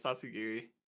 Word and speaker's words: Tatsugiri: Yeah, it's Tatsugiri.